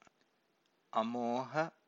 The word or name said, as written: amoha